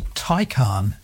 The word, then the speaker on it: Taycan: I might have said a Tay, but not that, is it? – No, it's a Taycan. – T-A-Y-N.